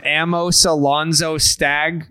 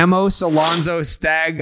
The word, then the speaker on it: Amos Alonzo Stagg: Stockton was also once home to Dallas Braden, who barely graduated from Amos Alonzo Stagg High. Did I say that right? – You nailed it, buddy. – Yeah. Amos Alonzo Stagg High School in Stockton before somehow getting into Texas Tech.